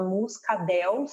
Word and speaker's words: Muscadelle: mas também existe uma uva branca que se chama Muscadel, se escreve Muscadelle, e ela também está lá.